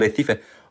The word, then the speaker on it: Recife: Mm, ich würde nach äh, Brasilien, Recife und, äh, das muss man auch so aussprechen, Recife und äh, ciao Paolo, eh, okay.